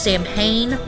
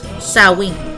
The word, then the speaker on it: Samhain: Which looks like it's pronounced Samhain, but it's Samhain, which was a fire festival to celebrate the end of the summer and the autumn harvest.